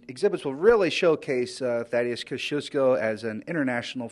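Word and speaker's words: Kościuszko: She watches the movie, she peers into the exhibit, and then she tells me I've been pronouncing the man's name wrong. – Tadeusz Kosciuszko. – Kosciuszko.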